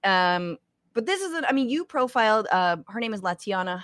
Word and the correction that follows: LaShawna: Halbert, if I'm pronouncing that right. – LaShawna, thank you.